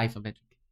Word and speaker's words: isometric: porque en inglés se pronuncia isometric.